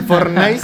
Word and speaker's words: Fortnite: ¿Cómo que Fortnite? Se dice Fortnite. Ah, bueno, el, Fornice el Fortnite.